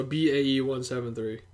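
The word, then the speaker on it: BAE173: The group name is pronounced for pronouncing each letter in the number, so BAE 173.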